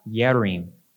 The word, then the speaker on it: Yearim: And it's returned to a city called Kiryat Yearim, which means a city of forests. And it's, ironically, another J word that is pronounced with a Y, Yearim.